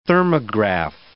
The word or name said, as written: thermograph